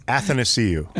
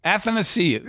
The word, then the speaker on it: Athanasiou: Athanasiou. – Okay, there you go. – Do it. – Athanasiou.